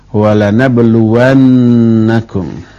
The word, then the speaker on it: وَلَنَبْلُوَنَّكُمْ: Lalu masuk huruf lam taukid, dibacanya pendek, walanablu. Lam taukid ini maknanya pasti, sesungguhnya benar-benar. Terus masuk huruf nun di akhirnya, disebut nun taukid thaqilah, walanabluwannakum.